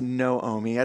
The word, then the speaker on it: Naomi: It's written Naomi, but it's actually pronounced Noomi.